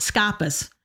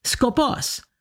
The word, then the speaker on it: skopos: And she was pronouncing scopos, scopus, S-K-O-P-O-S, scopus. This, it's scopos, okay? If you're going to use the term, say it correctly.